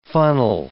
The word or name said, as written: funnel